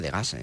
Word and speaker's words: Degas: Degas, lo pronunciamos en castellano, y por allí prácticamente pulularon la mayor parte de los artistas buscando temas de inspiración.